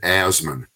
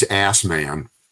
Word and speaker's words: Asman: His name is David Asman. Some people say he's Ass Man. His name is spelled A-S-M-A-N.